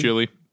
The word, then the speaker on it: Chile: How do you pronounce Chile in English? – That's actually it. – You just say Chile? – Chile. – Oh, okay. – Everybody else says Chile.